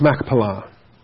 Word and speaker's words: Machpelah: It's not Mach, it's Mech, with a cough. Machpelah. "That he may give me the cave of Machpelah, which he owns, which is at the end of his field."